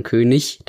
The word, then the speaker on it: König: Ja, genau, gerade bei IG, wie bei König, heißt nämlich nicht König, sondern König tatsächlich.